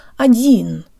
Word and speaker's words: один: guys, and today you learn how to pronounce the word один, which means one or alone. Tell your brain the O sounds like an A-flavored schwa, and let's say it with good Russian default mouth position.